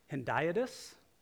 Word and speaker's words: hendiadys: Hendiadys.